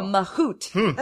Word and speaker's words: mahout: A mahout is a person who wrangles or drives what animal? – How do you spell that? – M-A-H-O-U-T.